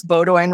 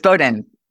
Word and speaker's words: Bowdoin: First of all, did I pronounce Bowdoin right? – No, it's Bowdoin.